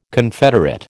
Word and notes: confederate: American English

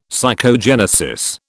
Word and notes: psychogenesis: British English

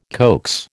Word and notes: coax: American English

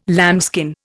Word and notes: lambskin: Indian

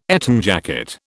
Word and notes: Eton jacket: British English

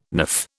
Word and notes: niff: British English

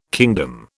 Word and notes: kingdom: British English

